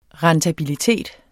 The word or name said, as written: rentabilitet